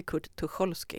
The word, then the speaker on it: Kurt Tucholsky: Men hade du då, när du blev kontaktad, hade du själv kommit i kontakt med eh, Kurt Tucholsky och uttalar jag hans namn rätt när jag säger Kurt Tucholsky? – Ja, absolut. Ja. Nej, jättebra uttal tycker ja, jag. Tack ska du ha. Ja, Tucholsky, absolut.